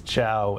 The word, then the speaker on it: Chao: Look, my last name is Chao and C H A O, and 90% of people can't spell it.